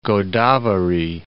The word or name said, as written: Godavari